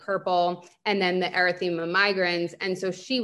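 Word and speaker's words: erythema migrans: Like I started getting that erythema migraines, I think. Is that how you say it? Yes.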